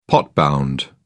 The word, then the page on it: pot bound: British English